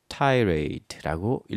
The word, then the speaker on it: tirade: 또 tired다라는 말, tired다라는 말은 tired다는 거는 길고 신랄하게 돌려서 말하는 거니까 길고 신랄한 비난을 tirade라고 읽어주시면 되겠어요.